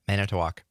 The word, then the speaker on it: Manitowoc: Yeah, Manitowoc.